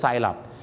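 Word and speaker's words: syrup: ซิรัปก็คือน้ำเชื่อมครับแต่อย่าลืมนะครับผมเคยไปลองแล้วนะฮะที่ร้านไอศกรีมใจกลางเมืองกรุงเทพดาวน์ทาวน์แบงคอกมาแล้วก็ลองไปเล่นดูครับกับพนักงานครับหนูจ้าเดี๋ยวพี่ขอซิรัปนิดนึงได้ไหมจ้าอะไรนะคะขอซิรัปนิดนึงจ้าอะไรฮะขอไซรัปจ้าไม่พูดตั้งแต่แรกแล้วฮะดังนั้นนะครับอย่าลืมครับคุยกับคนไทยก็ต้องเป็นไซรัปแต่ถ้าคุยกับฝรั่งนะครับถ้าเป็นไปได้ก็ออกเสียงว่าซิรัป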